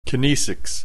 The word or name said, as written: kinesics